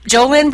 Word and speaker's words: Jolynn: then Jolynn Braley, that's spelled J-O-L-Y-N-N, Braley, B-R-A-L-E-Y.